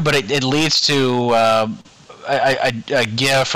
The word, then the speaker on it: GIF: I believe it's pronounced yif. It's a soft G. Yif.